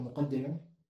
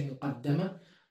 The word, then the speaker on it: muqaddimah: And you can pronounce it muqaddama. Both are permitted. If you say muqaddima, then this book is introducing you. The book itself is introducing you. And you say muqaddama, then I'm putting forward something that will introduce you.